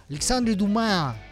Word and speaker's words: Alexandre Dumas: Alexandre Dumas, ya saben que se dice así en francés.